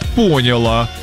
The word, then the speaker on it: поняла: Ей искренне непонятно, когда она слышит «поняла». – Поняла? – С ударением на «о».